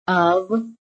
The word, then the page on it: OF: American English